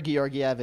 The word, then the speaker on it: Georgiev: So many people have different pronunciations of it, so I guess. I'm just going to stick with the most common, Georgiev, right? I've heard Georgiev, I've heard all of it. – It's for sure Georgiev.